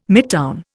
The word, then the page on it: midtown: Indian